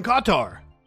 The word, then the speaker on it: Qatar: You're from Qatar. – Yes. – Did I say it correctly, or did I say it kind of American? – That's correct.